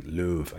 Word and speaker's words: Löw: Löw, I keep saying his name wrong because of the umlaut, and then in German you pronounce the W like a V in English.